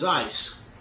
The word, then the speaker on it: eis: Now, the word for in is eis, and it's spelled transliterated as E-I-S, and it generally would take into the idea of into, onto, to, towards, for, and among.